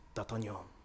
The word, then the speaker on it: D'Artagnan: In Man in the Iron Mask, Gabriel Byrne has an Irish accent, but he pronounces his name extremely French, and it calls himself D'Artagnan, and it's really good.